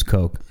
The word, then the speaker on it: Koch: I have no idea who owns Coke. – It's not the Koch brothers. – Nope. No. – The Koch. The Koch brothers. The Koch brothers. – They pronounce it Coke.